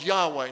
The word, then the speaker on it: Yahweh: scholars have concluded that the original pronunciation of the tetragrammaton was Yahweh.